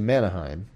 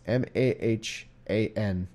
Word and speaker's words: Mahanaim: And it came to pass, when David was come to Mahanaim, I keep doing that wrong. It's Mahanaim.